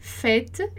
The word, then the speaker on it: fête: A gente tem que reter que o é fête, ele vai ser pronunciado com som de é, tá? Como tête, que é cabeça. Fête, tête.